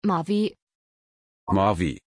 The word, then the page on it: Mavi: German